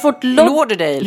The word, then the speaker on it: Fort Lauderdale: Det heter tydligen Fort Lauderdale. – Lauderdale. – Ja. – Men jag hade ingen aning. Jag tror det är Fort Lauderdale. Fort Lauderdale. – Ja. Inte dig. – Nej. Fort Lauderdale. – L-E.